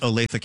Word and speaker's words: Olathe: And I can tell you, Elena, it is pronounced Olathe, Kansas, because I was recently there and I mispronounced it. – Ah. – And I was corrected.